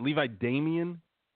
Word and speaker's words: Levi Damien: Is it Levi Damian? – That's correct, yeah.